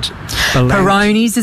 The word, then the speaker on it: Peyronie's: Is it Peyronie's and... – Peyronie's is another one.